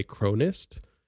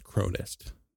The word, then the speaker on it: Krownest: I think I heard them say Cronist, but it's spelled like known, K-R-O W-N-E-S-T, but I think it's pronounced Cronist.